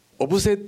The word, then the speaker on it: 小布施: あおオブセっていうのが正しい発音らしいんですけども、